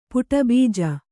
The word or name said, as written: ಪುಟಬೀಜ